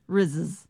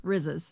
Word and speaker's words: Rizzs: Rizzes? R-I-Z-Z-S. How would you say it? – R-I-Z-Z-S? – Yeah. – Rizzes. – Would you say Rizzes? – Rizzes. – Rick Rizzes. – Yeah, Rizzes.